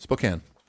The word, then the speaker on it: Spokane: By the way, Spoken Washington. – Spoken? Did I say it wrong? – Spoken. – Spokane. Spokane? How do you say it? – Spokane.